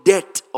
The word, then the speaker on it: debt: Also means purchasing back or paying for the debt of sin. D-E-B-T. The B is silent, right? It's not debt. You see, how about if somebody dies? That is not debt. It is death.